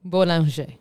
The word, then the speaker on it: Bollinger: Dinos lentamente cómo se dice. – Bollinger. – Boulanger. – Perfecto.